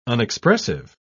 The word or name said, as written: unexpressive